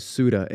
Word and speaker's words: Ceuta: That's what the English pronunciation is. It's actually Spanish, like actual Spanish. – Okay. All right. – So, you know, it's not pronounced that way, but the English pronunciation is Ceuta.